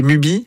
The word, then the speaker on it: Mubi: Et comment ça s'écrit? C'est Mubi? – M-U-B-I. – Tout M-U-B-I.